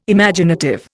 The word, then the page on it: Imaginative: Indian